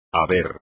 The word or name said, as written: haber